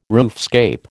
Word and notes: roofscape: American English

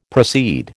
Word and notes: proceed: American English

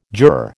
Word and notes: juror: American English

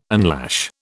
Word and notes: unlash: British English

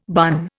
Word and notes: bun: Indian

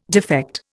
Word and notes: defect: Indian

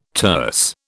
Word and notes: terse: British English

